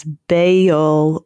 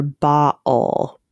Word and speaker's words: Baal: Oh, Wikipedia lists the pronunciation as Baal or Baal, but in both cases, emphasis on the first syllable, but there are two syllables in either pronunciation that are listed there. Yeah, but that A would definitely be an ah sound in the original language.